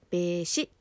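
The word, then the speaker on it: 米紫: 桂米枝さん、お米に紫と書いて米枝と読みますが、 1974 年京都市のお生まれです。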